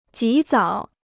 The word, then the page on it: 及早: jí zǎo